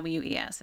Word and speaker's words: Clowes: I think it's how you pronounce his name. It's Clowes. It's his last name.